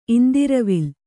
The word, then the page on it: ಇಂದಿರವಿಲ್: indiravil